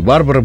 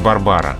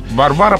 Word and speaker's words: Барбара: Барбара, потому что в польском языке ударение всегда на предпоследнем слоге, вот, а поскольку Барбара Брыльска – полька, то мы и говорим Барбара Брыльска.